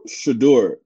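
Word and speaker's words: Shedeur: How do you pronounce his name? Shadur? – Shadur.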